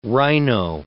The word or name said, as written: rhino